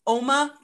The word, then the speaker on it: Uoma: So one of mine is Oma Beauty and it's spelled U-O-M-A.